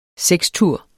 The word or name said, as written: sekstur